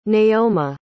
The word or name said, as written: neoma